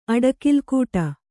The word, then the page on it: ಅಡಕಿಲ್ಗೊಡೆ: aḍakilgōḍe